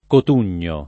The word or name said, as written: Cotugno